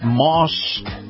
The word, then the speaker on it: Maus: Moss Acura. Now, Moss is spelled M-A-U-S,